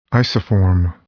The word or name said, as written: isoform